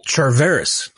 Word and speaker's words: Charvarius: Charvarius.